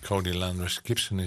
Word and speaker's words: Cody Landrus Gibson: En Cody Landrus Gibson is een singer-songwriter uit Chattanooga. Zeg ik dat goed? Ik denk het wel. – That's right, Mr. Okay.